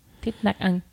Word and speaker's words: Thich Nhat Hanh: It's how do you say it? – Not. – Nhat. Nhat. – Nhat. – Nhat. – Anh. – Anh. – Thich Nhat Hanh.